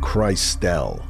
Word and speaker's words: Christelle: Yeah, it's spelled like crystal, but it says pronounced Christelle.